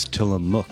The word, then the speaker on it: Tillamook: Given your internet search, I'm surprised you know it's Tillamook. – Tillamook. Well, they don't tell you how to pronounce it. It just looked to me like Willamook.